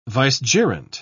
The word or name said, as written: vicegerent